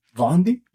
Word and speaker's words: Baundy: B-A-U-N-D-Y. – Vondi? – Yeah, Bon.